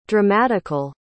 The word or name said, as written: dramatical